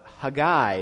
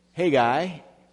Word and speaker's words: Haggai: Today we're going to be looking at the book of Haggai, if you pronounce it with the Hebrew way of putting the accent on the end syllable instead of on the front syllable like we usually do in America. Haggai, if you're an American.